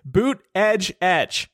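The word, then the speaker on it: Buttigieg: He also has been doing this thing where he gets the pronunciation of his last name printed on like walls and t-shirts and stuff, like in his campaign office. It says Boot Edge Etch.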